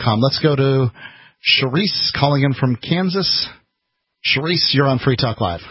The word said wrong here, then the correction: Cerise: Yes, Cerise. – Cerise. – No, no H.